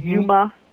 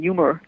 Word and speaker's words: Yuma: I think I might have mentioned to two of the three of you about this little boy whose name is Yuma, Y-U-M-A, Yuma. Sometimes when I say his name, people think I'm saying humor, but his name is Yuma.